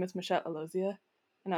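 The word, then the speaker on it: Alozie: Real quick, but since we were talking about Houston, I wanted to bring up Michelle, as you mentioned, and I'm going to say Alozia because that is what she said.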